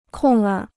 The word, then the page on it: 空儿: kòngr